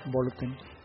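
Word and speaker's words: Bolloten: Esto, decíamos, es de Burnett Bolotén, por ahí busquen el nombre, Bolotén con doble L, diríamos Bolotén, se oye raro, pero es Bolotén en su pronunciación más conocida.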